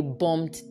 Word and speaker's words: bomb: They bombed the place. So it is bomb and comb. The B is silent.